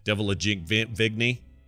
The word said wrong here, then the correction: Cara Delevingne: Is it Cara Delevingne? – Cara Delevingne. – It's not Delavigne.